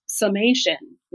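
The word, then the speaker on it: Sommation: the big sister of Access is Summation, which is spelled S-O-M-M for all of you who are listening and can't read my show notes, as in sommelier,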